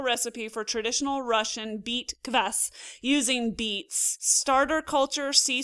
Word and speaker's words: kvass: So this is K-V-A-S-S. Do you pronounce it Kvass? How are you saying it? – So I know in America it's pronounced as the way you pronounce it. – Right. – I pronounce it as Kvass because I'm Polish born, and in Polish and in Russian it means acid. It's hard for me to pronounce it otherwise. So I say Kvass.